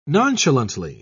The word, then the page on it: nonchalantly: British English